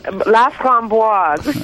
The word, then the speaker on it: Laframboise: Look, Barbara, one of your predecessors at the National Post was Donna Laframboise. I'm not sure if I pronounced that right. – Laframboise.